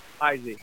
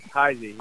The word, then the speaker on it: Heisey: Heisey. Heisey.